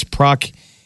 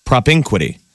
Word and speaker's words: propinquity: It's proquity. Propinquity.